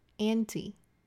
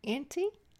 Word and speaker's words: anti: 字首常常会使用四个字母。A N T I, anti, anti, anti 两个发音都有。